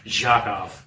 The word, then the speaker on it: Jakoff: And just so you know, it's spelled with a Q, right? It's JAQUUFFF. – So it's Jakoff? – Oh, like the Nobel Prize winner. – It's Jakoff. Joe Jakoff.